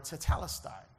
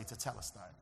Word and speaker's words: tetelestai: tetelestai. Everybody say tetelestai. You can say it like you're not stuttering.